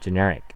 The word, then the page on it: generic: American English